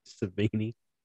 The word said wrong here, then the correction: Sabonis: Sabonis is the last name, because I believe his dad is Arvydas Sabonis. – Well, that's exactly how it looks.